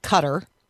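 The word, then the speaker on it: Qatar: Jessica, who deployed to the nation that is hosting the World Cup, and she says that the locals pronounce it cutter, not like guitar. Mm. Nancy, however, says, nope, it's like guitar.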